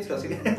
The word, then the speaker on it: psilocibina: Ahora sí lo dije bien, psilocibina.